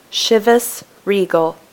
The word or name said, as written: Chivas Regal